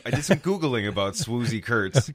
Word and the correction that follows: Swoosie: Turns out it's actually Swoosie. – Swoosie. – Swoosie, which it specifically says rhymes with Lucy rather than woozy.